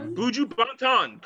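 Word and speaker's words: Buju Banton: I don't know if I'm saying this correctly, but it's Buju Ban. – Buju Bantan, correct.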